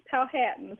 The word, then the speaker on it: Powhatan: And did I pronounce that city right, Powhatan? – Very close, Powhatan.